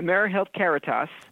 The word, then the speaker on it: AmeriHealth Caritas: AmeriHealth Caritas.